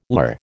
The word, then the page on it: lur: American English